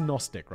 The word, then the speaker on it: Gnostic: Gnostic? It's just Gnostic, right? Silent G.